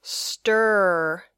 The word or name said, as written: stir